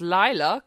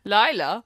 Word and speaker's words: Layla: The song Layla, pronounced Lila, because they're German, by DJ Robin. – And because it's funny. Say again. – Lila.